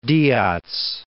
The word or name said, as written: Diaz